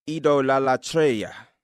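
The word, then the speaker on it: idolatreia (εἰδωλολατρία): In the Greek, the word idolatry is pronounced idolatreia.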